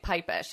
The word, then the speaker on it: Pipesh: Okay, Pipesh.